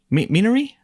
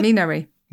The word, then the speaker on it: Minari: Minari? How do you say it? – Minari. – Minari. I got it right.